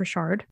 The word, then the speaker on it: Richard: It's spelled like Richard, but, you know, we're in Louisiana. It's pronounced Richard, apparently.